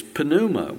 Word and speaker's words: pneuma: Yes, you pronounce the P. It's pneuma. We get our word pneumonia.